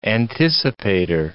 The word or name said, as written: anticipator